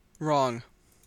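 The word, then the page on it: wrong: Canadian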